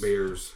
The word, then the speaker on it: Bayers: But it's spelled B-A-Y-E-R-S. It's not called B-E-A-R-S. – It's Bears. – Bears. – But it's pronounced Bears.